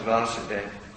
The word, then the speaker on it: Vasudeva: and he's called Vasudev, long A. When is the son, it's Vasudev. When is the father, it's Vasudev, short.